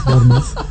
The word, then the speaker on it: Bournemouth: It's actually Bournemouth.